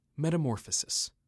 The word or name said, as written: Metamorphosis